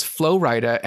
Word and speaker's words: Flo Rida: And let me tell you, it took me perhaps 5, perhaps 6 years to realize that his name is Flo Rida and it's spelled Florida.